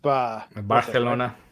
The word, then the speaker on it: Barcelona: You do it with. It's like the little lisp. – Barcelona. – Yeah. – It's like a TH.